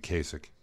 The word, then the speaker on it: Kasich: It'd be the hard C at the end of Kasich.